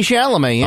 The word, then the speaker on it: Chalamet: Is that how you say his name? – Timothy Chalamet, yeah.